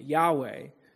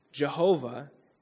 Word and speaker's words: Yahweh: Jesus claims the very name of God, Yud-Heh-Vav-Heh, Yahweh, Jehovah, if you want to pronounce it incorrectly, for himself.